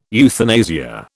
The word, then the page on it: euthanasia: British English